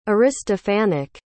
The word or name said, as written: aristophanic